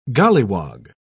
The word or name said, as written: golliwog